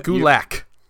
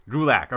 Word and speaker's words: Gulak: Gulak. – Gulak.